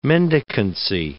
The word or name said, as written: mendicancy